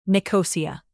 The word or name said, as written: nicosia